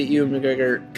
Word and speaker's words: Ewan McGregor: If we see Ewan McGregor coming back as an Obi-Wan standalone film,